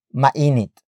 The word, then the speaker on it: mainit: Hindi is spelled as H-I-N-D-I. In this context, hindi means not. After that, we have mainit. Mainit. Mainit. Mainit is spelled as M-A-I-N-I-T.